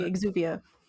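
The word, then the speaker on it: exuviae: Technically, by the way, exuvae is the, am I saying that right? Is the proper term for the shells, the exoskeletons they shed. – Yeah, the exuvia. – The husk. – Exuvia. Thank you.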